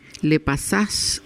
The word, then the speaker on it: Le Passage: Voy a deletrear tu escuela porque como está escrita en francés, a lo mejor si alguien la quiere buscar, se escribe LE y luego PASSAGE. Le Passage, sería en español, Le Passage.